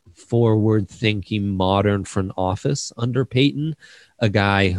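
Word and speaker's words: Paton: We are calling him General Peyton, because that's how it's pronounced, and because we want to see how many times we can try and say that and not say General Patton, which is what we desperately want to say.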